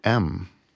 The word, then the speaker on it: Lankum: So Lancome, can you spell it? – Lankum. – Look, I would have gone Lankolm, Lancome. But okay, Lancome. So it's a good thing I asked.